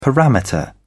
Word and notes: parameter: British English

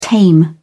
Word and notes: tame: British English

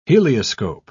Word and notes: helioscope: British English

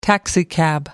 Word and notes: taxicab: American English

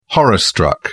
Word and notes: horror-struck: British English

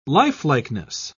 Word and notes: lifelikeness: British English

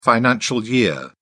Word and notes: financial year: British English

